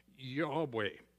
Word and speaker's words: Yahweh: Now, Lord is in the English, capital L O R D, that is the covenantal relational name for God, and Yahweh is the way in which it's pronounced,